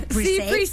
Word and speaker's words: pre-save: Pues estaremos muy pendiente de Encima de Él, ya me la voy a preserviar, o ¿cómo se dice? – Pre-save. Sí, pre-save.